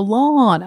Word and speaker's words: lawn: Lawn. It has sort of an aw. – Mm-hmm. – And then Lon Chaney is just ah. – Okay. L-O-N.